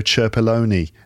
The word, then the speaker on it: Cerpelloni: I'm going to try and pronounce your full name at this moment. Fabio Cerpelloni. Did I pronounce it correctly? – Perfect. Perfect.